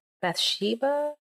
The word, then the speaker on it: Bathsheba: David, and was it Bathsheba? Mm-hmm. Did I pronounce it correctly? Mm-hmm. Perfect.